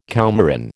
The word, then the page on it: coumarin: American English